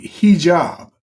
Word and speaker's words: hijab: Women have had enough of the hijab. No, I'm not saying that right. The hijab. – H-I-J-A-B. – J-A-B.